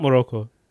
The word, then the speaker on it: Morocco: In Morocco? – Why did you say why Morocco? You say that? Yeah. Shoot, I don't know why I said because it's spelled Morocco. It's Morocco.